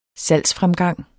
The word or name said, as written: salgsfremgang